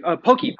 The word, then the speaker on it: Pocky: Would you go to Pocky? – Oh! I'm sorry. Pokey. – My bad. I'm that's sorry. Me. That's my bad Midwestern pronunciation. No.